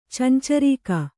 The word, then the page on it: ಚಂಚರೀಕ: cancarīka